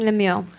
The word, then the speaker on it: Lemuel: Lemuel, am I saying it right? – Lemuel, yeah.